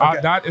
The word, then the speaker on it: Audate: That's actually the right way to say it. Okay. Audate is the French way. – Okay. – And that's how my dad says it. But the American version that I made up is Audate.